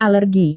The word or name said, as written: alergi